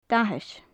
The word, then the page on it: dathas: gaelic